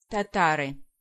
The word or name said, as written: Tatars